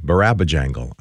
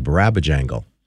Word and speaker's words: Barabajagal: Barbara Jangle. – Really? – Uh-huh. Barabba Jangle. Let me say it correctly. Barabba Jangle. B-A-R-A-B-A-J-A-G-A-L.